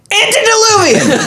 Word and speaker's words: antediluvian: I think I'm pronouncing this right. Antediluvian. – Antediluvian! A N T E D I L U V I A N, antediluvian.